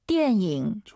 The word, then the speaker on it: 电影: 띠에닝 니은 발음 들리면 안 돼요. 띠엔잉 띠엔잉 다시 들어볼게요. – 电影.